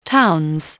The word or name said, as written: towns